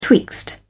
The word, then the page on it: twixt: British English